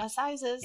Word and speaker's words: Assizes: Assizes.